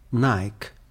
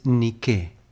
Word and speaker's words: Nike: Todos conocemos más bien la palabra con su pronunciación norteamericana, Nike. Pero Nike es Nike, la diosa alada de la victoria que acompañaba en la batalla a los antiguos griegos.